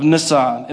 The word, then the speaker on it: Nisan: Nehemiah chapter 2, in the month of Nisan. And that is how you say it because I Googled it.